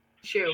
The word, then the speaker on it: Hsu: How do you say her last name? – Shu. – Shu.